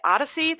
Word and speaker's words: OHDSI: These resources are all maintained by ODHSI. That's how you pronounce the acronym O-H-D-S-I.